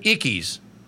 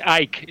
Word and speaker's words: Icke: Are you familiar with the British conspiracy theorist David Icke's? – Of course, it's Ike. It's pronounced Ike.